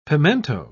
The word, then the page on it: pimento: British English